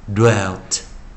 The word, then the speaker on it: dwelt: 下一个我们要教的是 d w d w 这个音啊，很少人知道该该怎么念啊，它念 do do do，先念的。再念 w do do do dwelt dwelt dwelt 好，那下一个我们教的是 k n，注意 k 加 n 的时候 k 不发音，这个一定要这叫 silent k，这也是要特别记得啊。